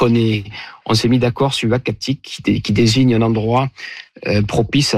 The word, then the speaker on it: Uhackatic: Alors, le nom, ça sera donc Uhacatic. Et ce nom-là, donc, il s'écrit U-H-A-C-K-A-T-I-C, nous a été proposé par le conseil des Inuits d'Ekunitchit, qu'on a contacté justement pour avoir leur avis.